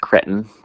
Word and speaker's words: cretin: Oh, cretin. Is one that dates back to 1770 and 80s. – It's pronounced cretin, please.